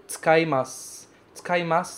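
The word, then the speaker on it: Tsukaimasu, tsukaimasu (使います): Tsukaimasu também tem uma mudancinha na pronúncia, porque você fala tsuka, tsuka. Esse U é praticamente mudo, praticamente não tem.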